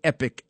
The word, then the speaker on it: Epoch: It's spelled E-P-O-C-H. Some people say the Epoch Times. Some people say the Epic Times.